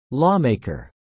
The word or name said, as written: lawmaker